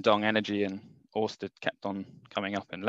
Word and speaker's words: Ørsted: based on the Danish scientist Hans Christian Ørsted, which is the correct way to say Ørsted, because he discovered electromagnetism.